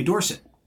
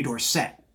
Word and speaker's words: Dorsett: When he was growing up for Aliquippa, it was Tony Dorsett. When he went to the NFL, he decided that people should pronounce his name Tony Dorsett.